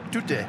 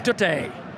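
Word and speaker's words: Tutte: Double substitution for Bury, replacing number 31, Neil Dance, is number 4, Aaron Andrew Toons. – What? – I think he might have said that wrong. – Toot. Yeah, this does say toot there, doesn't it? Tootay. – Tootay.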